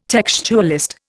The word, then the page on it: textualist: Indian